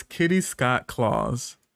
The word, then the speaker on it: Kitty Scott Claus: Next is Kitty Scott Claus. Which I love her name. Am I saying it right? Kitty Scott Claus? – Yeah.